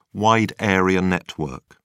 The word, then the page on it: wide area network: British English